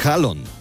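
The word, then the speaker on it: Haaland: a decir el nombre real de Erin Halland, que es Halon. La doble A en los idiomas escandinavos se pronuncia como una O.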